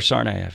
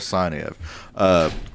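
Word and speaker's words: Tsarnaev: Pronounce it because I'll butcher it. Sarnayev. – Yohar Sarnayev.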